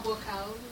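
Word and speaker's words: Buakaw: Bokau, which means white lotus. – White lotus. – I'll spell it. It's B-U-A-K-A-W.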